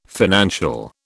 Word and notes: financial: British English

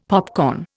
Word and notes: popcorn: Indian

